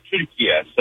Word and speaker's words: Türkiye: The way Turkish people pronounce it is actually the way it's said Türkiye.